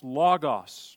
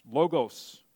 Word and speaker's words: Logos: In the Greek, you would hear it pronounced Logos, Logos.